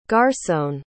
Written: garsone

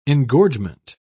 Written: engorgement

